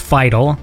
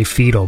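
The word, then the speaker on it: Fiedel: I think it is actually pronounced Fidel, although I think some people say Fetal, but I think it is Fidel.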